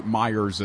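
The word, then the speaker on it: Meijer: The you know, you get to run to Meyer or whatever. I'm sorry, Myers is because you have to have you have to pronounce the S. It isn't actually there.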